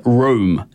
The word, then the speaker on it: rheum: And it's actually called rum, room. It's spelled like R-H-E-U-M, but it's like pronounced like room.